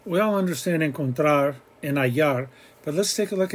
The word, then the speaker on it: hallar: We have encontrar and hallar. Hallar, the H is silent.